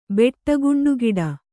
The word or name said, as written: ಬೆಟ್ಟಗುಂಡುಗಿಡ